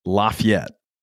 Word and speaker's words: Lafayette: Lafayette.